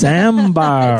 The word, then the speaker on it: Ambhar: By the way, it's spelled Ambhar. I didn't just say it really weird. Like, I think that's how you pronounce it.